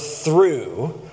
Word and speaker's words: through: But today, the sermon topic I have for you is the word through. The word through, T-H-R-O-U-G-H, T-H-R-O-U-G-H, one of those impossible to pronounce words for people who aren't English literate from birth, right? There's just too many silent letters.